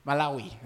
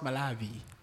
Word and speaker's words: Malawi: Malawi, that's how you pronounce it. It's pronounced Malawi.